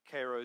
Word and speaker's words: Cairo: Now, this is kind of like Cairo, Georgia, Cairo, Egypt. Or Cairo, Egypt, right? Excuse me. We say it right.